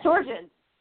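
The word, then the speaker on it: Sjögren's: either lupus or the Sjogren's syndrome. – Yes. – Sjogren's.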